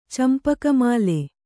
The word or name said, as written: ಚಂಪಕಮಾಲೆ